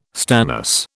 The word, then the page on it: stannous: British English